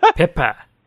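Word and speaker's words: PIPA: Do you view the SOPA Papa? Is that how you say it? – Pippa. – Pippa, there you go.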